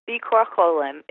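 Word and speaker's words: Bikur Cholim: was the Bikur Sholem room. Did I say that right? Oh my God. Am I getting my Yiddish better? – Bikur Sholem. It's actually Hebrew.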